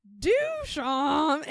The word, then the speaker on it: Duchamp: The first day of our seminar, our professor made us pronounce this name many times, and he said, think of it as the bray of a donkey. Mm-hmm. And then he started going, Duchamp, and it just went on for so long.